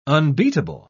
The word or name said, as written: unbeatable